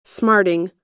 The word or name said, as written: smarting